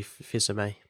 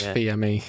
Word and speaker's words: Fils-Aimé: I thought it was Fils-Aimé. – Fils-Aimé. I go between saying like the French, I'm assuming it's French, the French way of saying it, and like how it's spelt.